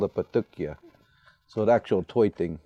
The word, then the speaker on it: Patukya: He's from Hopi, and he also makes tops, which is, how do you say it in Hopi? – In Hopi, we call it a Patukya.